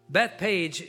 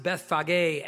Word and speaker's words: Bethphage: Bethpage in Hebrew, it's pronounced Bethphage, and it literally means the house of the unripe figs.